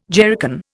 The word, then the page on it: jerrycan: Indian